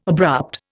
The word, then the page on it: abrupt: Indian